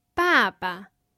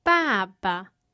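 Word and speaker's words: Baba (爸爸): We keep the tone for the first syllable, but for the second syllable, we have the neutral tone. – Baba. – Can you hear the difference? – Baba. – The first syllable has a falling pitch. The second syllable has no tone at all.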